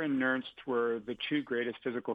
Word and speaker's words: Nernst: And Walter, is it Nernst? – That's right.